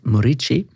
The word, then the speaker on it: Morici: Morici. – Ja, altså, skrives på en måte Moriki med K, men uttales... – Med Q, faktisk. – Q, mener jeg. – Q, ja. – Men uttales altså Morici.